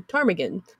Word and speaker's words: ptarmigan: When the town was to be incorporated in 1902, people suggested that the name Ptarmigan to name the town. Mm-hmm. However, since no one could agree on the correct spelling... – See? The silent P. – The silent P, yes.